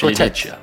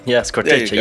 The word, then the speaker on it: Scorteccia: Yes, Scorteccia. Yeah, Scorteccia. Yeah, that's perfect. Scorteccia. You got to have a little bit of a lilt when you pronounce your vowels in Italian.